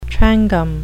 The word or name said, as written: trangam